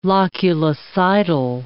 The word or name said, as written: loculicidal